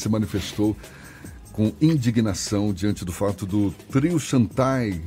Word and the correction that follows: Chantei: Não... Chantei. Ah, é, é. desculpa, é. e a gente estava discutindo Meu aqui. Você né? Tá em dia, Chantei. – A gente estava discutindo aqui a pronúncia, né? Chantai. – Chantai Away. – Ah, então é Chantei. Isso.